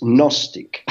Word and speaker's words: Gnostic: Second, it's hard to pronounce for a Frenchman in English because the G is not pronounced in English. It is pronounced in Greek and in French. So it's Gnostic.